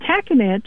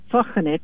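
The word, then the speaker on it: Tahkenitch: And Tackenich originally is pronounced Tochenich,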